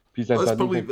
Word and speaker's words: Pisanthanakun: I can't pronounce it. Pisanthanakun. – That's better.